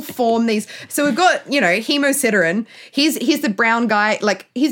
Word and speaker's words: hemosiderin: converted into something called hemosiridion. No, I've definitely said that wrong. Hemosiderin? Oh, no, this is why... – Hemosiderin.